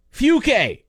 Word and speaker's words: Fucke: The town's name is spelt F-U-C-K-E, Fuke.